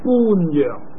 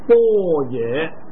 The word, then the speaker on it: 般若: 叫他做波野，咁啊呢两个字如果以中国嘅读音咧，就叫做般若，但系读般若咧就唔通嘅。我哋佢呢个音咧译过嚟咧，就叫做波野。